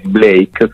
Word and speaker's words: Blayk: Il mio nome è Blake, scritto BLAYK, perché spesso succede che magari la pronuncia può far confondere, quindi tanti scrivono Blake, scritto Blake, invece è BLAYK.